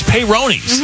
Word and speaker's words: Peyronie's: No, Peyronie's a beer. It's Peyronie's. Mm-hmm. That's how you say it.